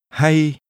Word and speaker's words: hay: The word or in Vietnamese is hay. – Listen and repeat. – Hay. Hay. Hay. – This is pronounced with a mid-level tone.